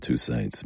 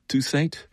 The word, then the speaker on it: Toussaint: And you can see why Peter Washington was mesmerized by Sullivan's approach to Alan Toussaint's music. And he said, oh, it's Toussaint. I actually saw Alan Toussaint in person, and he pronounced his name Alan Toussaint.